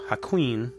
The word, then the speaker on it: Hakuin: She would not confess who the man was, but after much harassment, at last named Hakun. I hope I'm saying his name right. – How's it spelled? – H-A-K-U-I-N. – Hakuin.